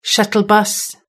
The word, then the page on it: shuttle bus: British English